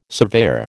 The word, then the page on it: surveyor: American English